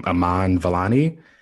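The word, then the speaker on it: Iman Vellani: I think you pronounce her name Aman Vellani.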